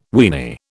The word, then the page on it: weeny: British English